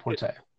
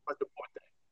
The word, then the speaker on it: Quattroporte: It's Quadriporte. – Quattroporte.